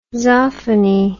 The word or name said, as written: zoffany